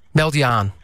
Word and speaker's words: meld je aan: Meidwaan, is that how you say it? Meidwaan. Meld je aan. Yeah, meld je aan.